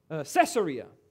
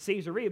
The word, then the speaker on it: Caesarea: Some of you may pronounce this city Caesarea, but I was corrected when I was in Israel. They don't pronounce it that way. They pronounce it Caesarea because it's named after Caesar.